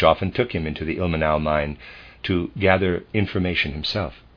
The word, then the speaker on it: Ilmenau: Ilmenau, I'm going to pronounce it that way, it's I-L-M-E-N-A-U, Ilmenau.